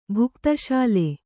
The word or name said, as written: ಭುಕ್ತಶಾಲೆ